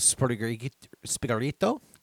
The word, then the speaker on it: Sprigatito: There was the choice of Sprigatito. – You are not pronouncing any T's whatsoever.